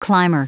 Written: climber

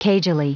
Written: cagily

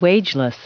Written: wageless

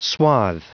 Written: swathe

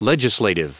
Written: legislative

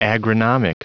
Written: agronomic

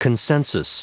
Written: consensus

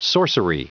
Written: sorcery

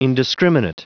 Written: indiscriminate

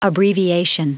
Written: abbreviation

